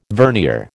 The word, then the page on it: vernier: American English